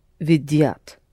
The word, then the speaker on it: видят: Они видят. – Mm-hmm. Accented on v.